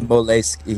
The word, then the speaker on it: Moleskine: It's Moleskine. He said he pronounced it Moleskine instead of moleskin. – Moleskine is the correct pronunciation. Moleskine is the American or the English version of it. But if you go to Italy, they'll say Moleskine.